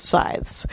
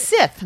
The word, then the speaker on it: scythe: I thought it was Sith, no? – No. No, Sith is the bad guys in Star Wars.